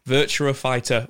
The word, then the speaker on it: Virtua Fighter: But for $7.99, you can download the Legendary Pack, which lets you essentially play the new game with a virtual, I was going to say it wrong again, Virtua Fighter 1 skin.